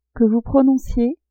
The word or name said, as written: que vous prononciez